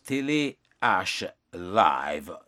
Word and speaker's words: Télé H Live: et puis Télé H Live, nous t'allons prononcer en anglais, on dit télé H Live, dans Ville Paris, dans le pays la France.